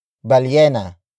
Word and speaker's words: balyena: Ang is spelled as A-N-G. It is the focus marker, which introduces the main noun of the sentence, and that is balyena. Balyena. Balyena. Balyena is spelled as B-A-L-Y-E-N-A. It means whale.